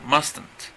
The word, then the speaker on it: mustn't: Es lo mismo, solamente que en vez de must, van a poner must not, que suena mustn't. Mustn't, okay? O también se pronuncia mustn't.